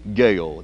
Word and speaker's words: Gueho: Gale. That last name is spelled G-U-E-H-O, Post Office Box Number 6250, New Orleans, Louisiana, 70174.